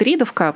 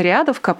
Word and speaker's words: Риадовка: А, во-вторых, отдельный еще тоже текст про то, как Кремль использует телеграм-каналы на примере издания, его все называют «Ридовка», потому что оно так по-дурацки пишется. Насколько я знаю, оно на самом деле называется Риадовка просто по наименованию смоленского топонима.